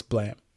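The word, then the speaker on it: Balaam: But it's spelled Balam. – Okay. – But it's Blam.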